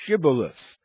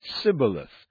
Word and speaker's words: Shibboleth: They adopted the method used way back in the times of the Judges when the Gileadites asked the fleeing Ephraimites to pronounce the word Shibboleth, and they couldn't. Instead, they said Sibboleth, and the one-letter difference cost them their lives.